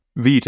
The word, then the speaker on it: Vite: Vite is how everyone I've talked to, yeah, on the team and everything.